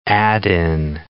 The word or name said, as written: add-in